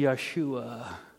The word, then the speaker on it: Yeshua: So Joshua's going, Joshua, how do you really say Joshua? You say Yeshua.